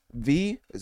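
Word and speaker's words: Vie: Vi, French for Life. – Ah, it's Vi.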